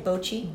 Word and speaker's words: bocce: And speaking of kids, we have a whole kids activity area where there will be bocce ball. – And is that how you say it? Bocce? – Bocce, yes.